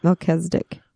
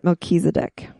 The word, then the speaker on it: Melchizedek: So the Mormons pronounce it Melchizedek. – The Melchizedek priesthood. – But everybody else pronounces it Melchizedek.